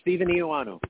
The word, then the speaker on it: Stephen Eoannou: No, it's Stephen Eoannou.